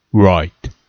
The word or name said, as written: write